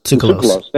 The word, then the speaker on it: Tsoukalos: How do you pronounce George's name? That's the founder. – Tsoukalos. – Thank you, Tsoukalos.